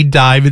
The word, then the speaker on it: DIIV: Toots and the Maytals, it's Pressure Drop here on 88.9, a band that originally called themselves D-I-V-E, Dive. It's still pronounced Dive, but because of legal reasons, they had to change it to D-I-I-V.